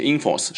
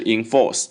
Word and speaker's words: enforce: 第二个单词 enforce，重音在后面，enforce 不要念成 enforce，是 enforce，它表示执法，哎，执法或者说使生效。